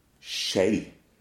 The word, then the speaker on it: skje: The word spoon in Norwegian is S-K-J-E, and you say she, she. – Yeah, so it's just literally S-K-J and S-J will just both be a sh sound.